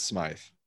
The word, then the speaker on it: Smythe: Although Durham Smith or Smythe or whatever his name is, because now there's evidently two pronunciations, but Durham Smythe. – He says it's Smythe, and yeah.